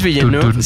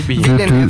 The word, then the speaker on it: Villeneuve: Denis Villeneuve, sí se dice así, Villeneuve.